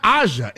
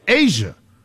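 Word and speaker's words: A'ja: You're not even saying it right. You're saying it's not Aja. It's Asia.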